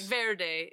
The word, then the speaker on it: Verde: And of course, Verde is spelled V-E-R-D-E. Like Verde is how you're really supposed to say it. – Right. – But nobody here, if you're from here, you just call it Verde.